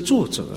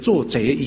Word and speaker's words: 作则: 一个领导者自己以身作则哎。 – 就是领导者以身来作则。 – 有的人发音不准，就变成做贼，以身作贼，不是以身做小偷了，不是了啊。为人发音不合格，以身来做贼啊，这个发音不对啊。